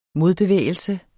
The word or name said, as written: modbevægelse